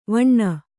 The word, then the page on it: ವೈನಿ: vaini